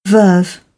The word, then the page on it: verve: British English; ɜː